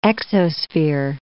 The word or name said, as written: exosphere